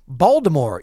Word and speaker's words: Baltimore: Baltimore. Yeah, of course. Uh, rhymes with Voldemort.